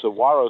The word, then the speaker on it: Saguaro: It's like guacamole is actually pronounced, the G is pronounced as a W. – Okay. – Saguaro Sisters. It's spelled with a G, but pronounced with a W.